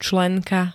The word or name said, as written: členka